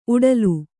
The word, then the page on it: ಊಟಲೆ: kannada